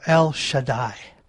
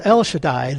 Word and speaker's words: El Shaddai: It's pronounced as like that of El Shaddai. El Shaddai, El Shaddai. Mm-hmm. Pronounced El Shaddai, this is best known of the El compound.